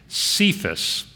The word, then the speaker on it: Cephas: But Jesus looks at him and said, "Your name is Simon, the son of John, but you will be called Cephas." That's how we pronounce that in English, which means Peter.